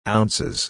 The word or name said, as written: ounces